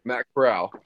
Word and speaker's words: Corral: Like Matt Corral, how do you say his last name? – Matt Corral.